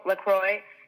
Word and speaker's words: LaCroix: I drink LaCroix, is that how you say it? – Yep. – Yeah, okay, I drink LaCroix, and I love to have whatever snacks are around.